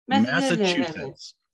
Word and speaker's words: Massachusetts: I can never pronounce that right for some reason. Massachusetts. – Massachusetts. – That's it. What he said.